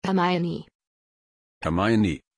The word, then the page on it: hermione: German